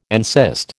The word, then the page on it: encyst: American English